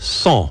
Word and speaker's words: cent: It's pronounced cent, which sounds like it's spelled S-A-H-N, but it's actually spelled, drum roll, C-E-N-T, cent.